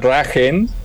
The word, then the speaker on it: Drachen: En alemán, la palabra dragón se escribe Drachen, que se pronuncia Dragen, pero yo no quería que nos dijeran los Rachen.